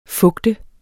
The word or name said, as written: FUGTENDE